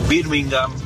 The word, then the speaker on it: Birmingham: Todos los equipos que terminan con ham, uh-huh, H-A-M, bueno, la H es muda. Ejemplo, Tottenham, Birmingham. Creo que la única excepción es West Ham, pero justamente porque se escribe separado.